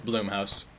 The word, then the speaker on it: Blumhouse: Is it Blumhouse or Bloomhouse? – It's Bloomhouse.